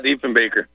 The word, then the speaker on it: Diefenbaker: I think there was a Diefenbaker in Ontario, if I'm saying his name right. – Diefenbaker.